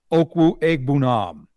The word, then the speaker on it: Okwuegbunam: Albert Okwuegbunam.